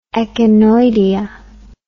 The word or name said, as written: echinoidea